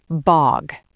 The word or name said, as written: bog